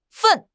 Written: fen4

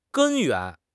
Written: 根源

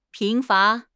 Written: 贫乏

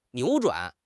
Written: 扭转

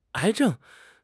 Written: 癌症

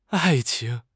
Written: ai4 qing2

